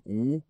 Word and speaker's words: woman: Prononciation, c'est a woman.